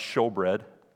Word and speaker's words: showbread: It says shewbread. That's an old English way of saying it, but it's show. It's pronounced showbread.